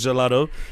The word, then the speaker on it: gelato: For somebody who is unfamiliar, like myself, who has seen the word but actually didn't know that that is gelato, and gelato. – But you're saying it like Italians. That's how Italians pronounce it.